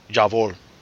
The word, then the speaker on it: Jawohl: I thought there was a V in there. – Well, it's a... Yeah, sorry, I always screw up my W pronunciations. It is more of a V sound. Jawohl.